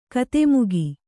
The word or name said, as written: ಕಥಾಮುಖ